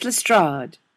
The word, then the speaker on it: Lestrade: "It's pronounced Lestrade," John told him defensively, though he sensed Neil knew perfectly well how to pronounce it, and had merely been screwing with him.